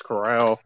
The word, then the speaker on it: Corral: I hate to be the pronunciation police or anything, but it's Corral.